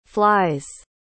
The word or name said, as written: flies